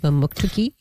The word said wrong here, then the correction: Mamotokati: No, Mamotokati. – Mamotokati. I missed something in there.